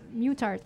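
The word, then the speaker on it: Muttart: Mutart, right? C'est comme ça qu'on le prononce? – C'est comme ça que moi, je le dis. Mais, tout, ben, voilà, tu le dis de même, je le dis de même.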